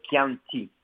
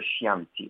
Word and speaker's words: Chianti: Tout comme on dit le Chianti, C-H-I, et non pas le Chianti, on dit du Chianti.